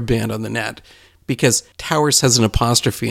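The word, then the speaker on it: Tow'rs: If you search Towers with an apostrophe instead of an E, so one syllable Towers, we're pretty searchable on Google. So you spell that T-O-W apostrophe R-S.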